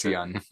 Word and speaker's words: Yeun: Steven Yeun was cast in Thunderbolts. – I wrote a Notes. – note. – Apparently a very influential character. Name rhymes with yum. – It's Yeun.